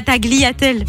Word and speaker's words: tagliatelle: Mais même, mais oui, non, en italien, GL, ça fait L. Donc, Donc c'est tagliatelle. – Tu dis pas tagliatelle.